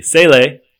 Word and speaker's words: Sele: This week, we're going to run over the Sele, Rhymes with Pele, mm-hmm.